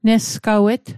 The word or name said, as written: nesgawet